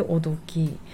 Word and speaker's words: 男時: えっ、ー、と漢字は男の時と書いておどき女の時と書いてめどきと読むんですよ。